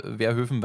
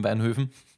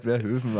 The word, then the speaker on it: Verhoeven: Damit ich den Namen jetzt gleich falsch ausspreche oder anders ausspreche wie vorher, ich habe es nämlich vergessen, Paul Verhoeven, Weinhoeven, Weinhoven, Hilfe. – Ich glaube, er ist Verhoeven, aber...